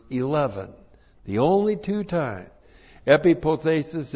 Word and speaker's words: epipothesis: E-P-I-P-O-T-H. Long E-S-I-S, epipothesis.